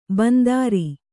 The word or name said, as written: ಬಿಂದಾರಿ